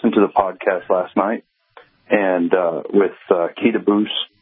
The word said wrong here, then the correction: Bussy: Keita Bussy. Yep. Rhymes with fussy, yeah.